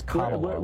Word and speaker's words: Kamala: Like camel? – Yeah. – Really? It's Kamala.